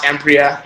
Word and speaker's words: Emprea: Is it Emprio? Spell it. – Yeah, E M E-R-E-A. It's just pronounced Empria. It can mean whatever anybody wants. E-M-P-R-E-A, Empria.